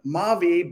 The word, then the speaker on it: Mavi: So what we've done at Mavi, and side note, Mavi by pronunciation, not by spelling, means my life in French.